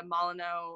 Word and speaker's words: Molyneux: So I don't want to see the Molyneux, I hope I said that right. – Yeah, you did.